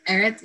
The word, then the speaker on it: Aerith: Aerith? – Aerith,